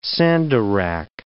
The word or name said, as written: sandarac